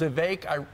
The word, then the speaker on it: Vivek: He said, you know, my name's Vivek. It rhymes with cake.